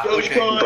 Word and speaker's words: Dogecoin: Um, the technically correct way to say it apparently is Dogecoin. Ah, Dogecoin. Okay. That's correct.